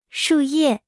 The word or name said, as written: 树叶